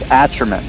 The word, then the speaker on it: Atrament: is it pronounced Atrament? – It's Atrament.